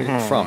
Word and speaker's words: Frum: So my feeling is, by the way, I had an exchange with David Frum. Did you correct me? Frum.